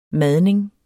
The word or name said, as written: madning